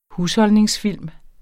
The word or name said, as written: husholdningsfilm